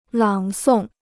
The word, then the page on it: 朗诵: lǎng sòng